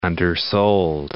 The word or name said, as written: undersold